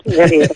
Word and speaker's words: Guerriero: Pues, bueno, yo el último que he leído, que me ha gustado muchísimo, es La Llamada, de Leila Guerriero, que siempre lo digo mal, Guerriero.